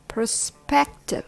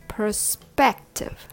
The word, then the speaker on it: perspective: First, purr. The purr rhymes with her or fur. Then spective. The S sounds like a hissing snake, s, followed by a P sound, p. Together it's sp, sp, active. The last syllable, tiv, has a schwa sound of, of, spective, perspective. Three syllables with the stress on the second syllable, spect, perspective.